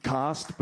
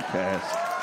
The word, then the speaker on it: caste: It's pronounced cost, but that's how they say it in England, and fuck that country entirely. So I'll just keep saying caste.